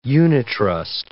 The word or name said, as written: unitrust